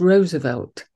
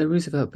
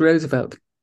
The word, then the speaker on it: Roosevelt: The pronunciation is Roosevelt. – Oh, right. – Everybody obviously you read Roosevelt and you go Roosevelt, but the pronunciation is Roosevelt. And they're very stickler. They're sticklers about that.